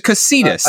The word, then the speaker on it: Cocytus: So the lair, I do not know how to pronounce this. It's C-O-C-Y-T-U-S. Is it Cocytus? Is it Cocytus? Is it Cocytus? – I believe it's Cocytus.